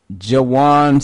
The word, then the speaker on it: Juwan: Here we go. Pronouncing it. All right. Juwan Seals. According to the Oklahoma State website.